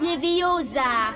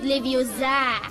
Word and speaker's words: Leviosa: Und übrigens hast du die falsche Betonung. Es heißt Leviosa und nicht Leviosa.